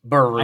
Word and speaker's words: Birria: Games was Birria. – I'm saying it wrong. Yeah, B-I-R-R-I-A.